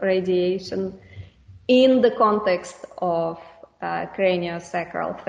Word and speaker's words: craniosacral: I'd like to move on and ask you about craniosacral therapy. Am I pronouncing it correct? – Yep. You're getting them the right way around. A lot of people go sacrocranial.